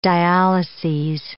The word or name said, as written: dialyses